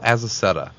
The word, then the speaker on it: Azaceta: It's a name that I constantly mispronounced, and he said it's Spanish. But it's Paul Azaceta.